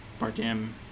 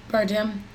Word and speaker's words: Bardem: Bardem. Bardem.